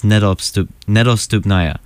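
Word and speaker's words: Nedostupnaya (Недоступная): and it looks like it starts with the letters H and E, but it's pronounced Nedostupnaya.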